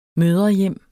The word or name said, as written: mødrehjem